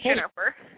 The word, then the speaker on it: Jennifer: Jennifer. – Oh, spell that one for me. – J-E-N-N-I-F-E-R. – J-E-N-N-I, oh, Jennifer, okay, there we go.